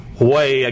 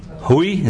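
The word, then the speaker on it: Haüy: There was a French social reformer whose name was Valentin Huy, I guess that's the way you pronounce it, H-A-U-Y. Huy, is that the way you say it? Huy, okay, is recognized as the founder of the first such school in Paris in 1784.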